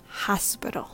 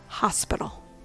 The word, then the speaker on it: hospital: Remember, the stress is on the first syllable, hos. Use the schwa sounds for the second and third syllables, p-ro. Say it smoothly, all in one breath. Hospital, hospital.